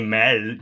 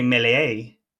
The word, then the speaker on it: Méliès: You don't say Mel, you say Melier.